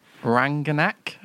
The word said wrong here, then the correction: Rangnick: Have I said that right? – Rangnick. – Rangnick.